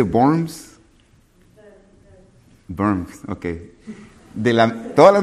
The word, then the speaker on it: Worms: De Worms. Se escribe en W, dicen que se menciona como V, Worms.